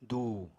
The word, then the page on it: du: apatani